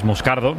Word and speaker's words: Moscardo: Este sí, como bien ha pronunciado Unai, es Moscardo, es brasileño, sí.